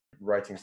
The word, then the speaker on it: writing: Yeah, that joke is particularly funny if you're looking at the script, since writing starts with a silent W and arithmetic starts with an A.